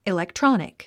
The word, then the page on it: electronic: ilektrɑ’nik; イレクトロォニック